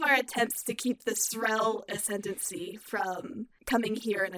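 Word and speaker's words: Xrell: It's X-R-E-L-L. – Yeah, it's one syllable. – Zrel.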